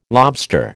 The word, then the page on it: lobster: American English